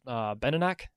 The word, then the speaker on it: Benenoch: I think how you pronounce is Benenach, B-E-N-E-N-O-C-H.